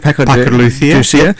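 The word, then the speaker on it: Paco de Lucía: Paco de Lucia.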